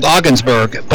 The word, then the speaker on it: Ogdensburg: Another town right on the Canadian border, it's called Ogdensburg, but actually it's spelled like Ogdensburg. The D is silent.